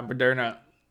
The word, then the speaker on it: Moderna: My sister and my mom both got Moderna. How do you pronounce it? – I say Moderna. Whatever. – Yeah, Moderna.